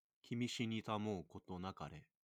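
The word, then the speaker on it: Kimi shinita mo koto nakare (君死にたまふことなかれ): This one right here. – Kimi shinita mo koto nakare.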